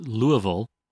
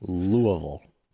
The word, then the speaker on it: Louisville: All right, next we have an email from Matt in Louisville, Kentucky, pronounced Louisville by locals. – Louisville.